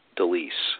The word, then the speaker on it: deLise: Keith, you might want to, I don't know your redo situation here, but the last name is pronounced deLise.